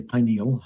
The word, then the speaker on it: pineal: And the third eye relates to the pineal gland, and the, or you can say pineal, however you want to say it.